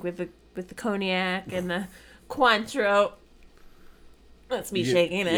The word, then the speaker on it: Cointreau: We thought it was Cointreau. – Yeah. – It's not. – It's spelled Cointreau, but it's French, so it's pronounced Cointreau.